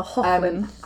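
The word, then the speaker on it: Hoechlin: Hecklin. – Is that how you say that? – It's Hochlin. Spelled, yeah, we're all learning Hecklin. Something today. – Tyler Hochlin.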